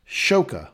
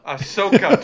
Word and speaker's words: Ahsoka: you you did it both wrong and right. You said uh. Shoka. Ahsoka Tano.